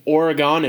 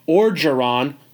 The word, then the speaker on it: Orgeron: I was pointed out after two podcasts ago that I was saying Oregon. It's Ed Orgeron.